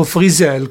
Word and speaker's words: Frizzell: Lefty Frizzell, o Frizzell come ho scoperto che si dovrebbe pronunciare,